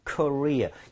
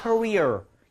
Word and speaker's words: Korea: So K를 대문자로, obviously, Korea. 이거 발음할 때요, 혀를 막 꼬지 마세요. – 아. – 그러면 우리 왜 커리어 있죠? 커리어. – 아. – Career. 이렇게 들릴 수 있기 때문에 실제로 이거 실화인데요.